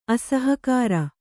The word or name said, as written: ಅಸಹಕಾರ